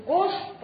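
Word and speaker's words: gauche: Она не читается на конце французских слов, если над ней нет значков. «Рив» – последнюю букву зачеркиваем, «Гош» – последнюю букву зачеркиваем.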